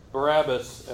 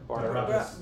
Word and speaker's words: Barnabas: And it called Barabbas and Paul to go from Antioch to Jerusalem. – Antioch, Barnabas, Sorry, what did I say? – Barnabas. ですから、パウロがバルナバスやバルナバと共にアンテオケというところからエルサレムに登ってきました。 – And so, Paul's mentioning, Barnabas here because Barnabas went with him on the first missionary journey.